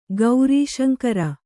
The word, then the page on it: ಗೌರೀಶಂಕರ: gaurī śaŋkara